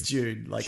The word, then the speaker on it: Dune: Dune. It's Dune. Sand Dune. – June. – Yeah, like the month.